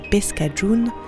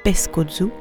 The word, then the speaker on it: pescajoun: La première, prononcée pescajoun, est un terme masculin qui signifie le beignet, la pâte frite à la poêle et la version est salée. Et la seconde, pescajoun, prononcée pescozu, signifie espèce de pâtisserie où il entre beaucoup d'œufs, crêpes sucrées.